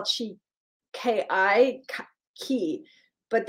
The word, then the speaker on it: Qi: Now, Qi, Q-I, is pronounced Qi.